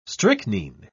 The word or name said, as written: strychnine